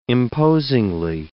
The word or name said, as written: imposingly